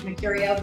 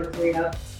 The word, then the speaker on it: Mercurio: I won't pronounce it like it should be. I'm pronouncing it for the U.S. and Canadian audience. So it's like the planet Mercurio, with the go at the end, you know, Mercurio. It's not Mercurio, so you don't have to say it that way.